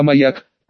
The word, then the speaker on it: маяк: «Господин, они маёк зажгли», — сказал один из нанятых мною мужиков, что крутили барабан. «Правильно говорить, не маёк, а маяк», — поправил я матроса, а затем с угрозой в голосе продолжил.